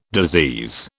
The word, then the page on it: disease: British English